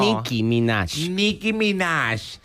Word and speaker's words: Nicki Minaj: Bueno, en American Idol, Nicki Minaj, ¿se pronuncia así o no? – Nicki Minaj.